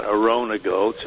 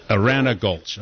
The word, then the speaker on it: Arana Gulch: First of all, you're putting the emphasis on the wrong syllable. It's Arana Gulch, not Arona Gulch, or not Arena Gulch. It's Arana. – Arana Gulch, okay.